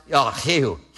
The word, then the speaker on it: Jehu: And we say in English, Jehu, Because we use the J, but Hebrews don't use that. They use the spitting sound. So literally, it would be pronounced Yahu, Yahu. That's how they would pronounce it.